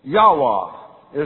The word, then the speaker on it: Yahweh: Yahweh is the closest pronunciation.